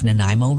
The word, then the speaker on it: Nanaimo: N-A-N-A-I-M-O, Nanaimo. Maybe it's just because I grew up here. I don't know. But some of the mispronunciations, Nanaimo, Nanaimo, Nanaimo, Nanaimo. I literally heard people saying these kinds of things.